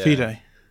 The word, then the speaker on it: FIDE: FIDE is how you pronounce it, is it? – Yeah. – FIDE.